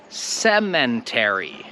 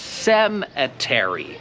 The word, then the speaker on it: cemetery: I z mojego doświadczenia to bym się założył, że wymawiasz to słowo niepoprawnie jako cemetery. To chcę, żebyś mi pokazał, gdzie w tym słowu jest litera N. I jak widzisz, nie ma litery N. Wiem, że po polsku to jest cmentarz, ale po angielsku, jak widzisz, tam nie ma N i to słowo się wymawia cemetery. Cemetery.